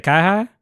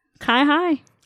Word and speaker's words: CyHi: C Y H I. – C Y. Yeah, I don't. – How do you say it? Kai Hai.